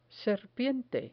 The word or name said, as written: serpiente